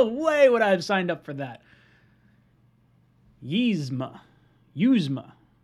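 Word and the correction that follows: Uzomah: See, everyone's telling me I'm pronouncing it wrong. It's Uzama.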